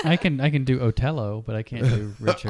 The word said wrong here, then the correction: Othello: It's pronounced Othello.